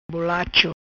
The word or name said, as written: Bolacho